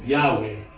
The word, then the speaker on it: Yahweh: He's using, in the Old Testament, the word Lord, as we have it, is a way of speaking about that divine name, Yahweh. Some think it's pronounced as that.